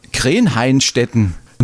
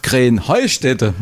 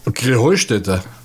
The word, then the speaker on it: Krähenheustätte: Ja, ihr sagt immer Krenheinstetten. Und dabei heißt es doch Krähenheustätte, oder wie? – Krähenheustätte. – Ja, genau, und das die Betonung auf dem Heu.